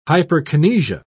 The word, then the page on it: hyperkinesia: British English